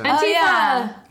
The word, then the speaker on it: Antifa: I say Antifa. Antifa. We both said it differently.